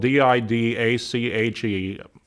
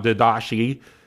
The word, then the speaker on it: Didache: Now, the Didache is spelled D I D A C H E. I've struggled with the pronunciation of that. Thanks to Google, I found out how it's pronounced. It's not Didache, it's Didache.